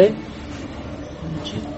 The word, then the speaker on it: Nietzsche: Nietzsche. – Is that how you say his name? Nietzsche. – Huh? – Nietzsche. Nietzsche. Nietzsche. Okay, that's better because Nietzsche in Bengali means lower, which is about kind of right.